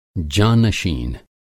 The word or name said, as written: जाँ-नशीन